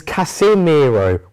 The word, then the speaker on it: Casemiro: Casemiro. Or is it? – Oh no. – His name is Casemiro with an I rather than an E.